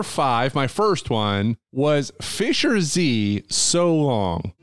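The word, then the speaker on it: Fischer-Z: would pronounce the name of Fisher Z as Fisher Z, whereas in the U.K., where they're from, Z would be Zed. – Fisher Zed. So it'd be Fisher Zed, except their specific dialect, they don't pronounce the R. So it's Fish's Head.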